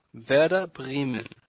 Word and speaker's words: Werder Bremen: Werder Bremen.